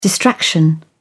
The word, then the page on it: distraction: British English